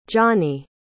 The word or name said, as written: jonny